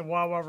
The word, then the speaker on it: Wawa: You know, I wanted to go up to someone and go, there's no S in Wawa. – Wawa. – I remember that. – It's so funny. – It was really cool seeing like the Wawa references.